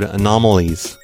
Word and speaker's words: anomalies: But I have received numerous complaints about my pronunciation of the word anomalies. Anomalies. Is that how you pronounce it? I kept saying anomalties on the last episode because I was saying the cacti were scanning their bodies for anomalies, and I honestly thought that's how it was pronounced.